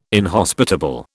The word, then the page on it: inhospitable: British English